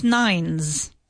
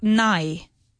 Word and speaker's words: nine: It's not nigh. Nigh doesn't exist in English. It's nine. So that second N needs to be pronounced very clearly. Because if you don't pronounce it clearly, it sounds like nigh.